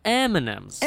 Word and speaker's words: M&M's: Последний звук в слове and – в живой речи практически всегда не произносится. Ну, только если вы хотите специально его акцентировать. Да. Поэтому то, что тает во рту, а не в руках, и коричневого цвета, зовется не иначе, как M&M's.